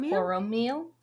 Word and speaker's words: Poromiel: Poromiel.